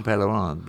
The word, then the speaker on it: Pellerin: No, it's Jean Pellerin.